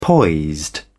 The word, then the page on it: poised: British English